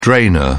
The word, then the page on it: drainer: British English